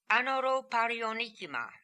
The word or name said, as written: Anoroparionikima